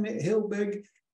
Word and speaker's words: Helbig: And we've got the expert here, Diane Helbig.